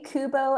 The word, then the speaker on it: Kubo: So A, Kubo and the Two Strings.